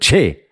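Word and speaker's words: Kyereh: Da hat, mich der, da hat mich der Pressesprecher überzeugt, dass er ja jetzt so ausgesprochen werden möchte, am liebsten. Wie? Wie das, wie das in Ghana ausgesprochen wird. Che. Che, glaube ich, ungefähr so.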